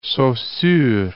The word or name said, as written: Saussure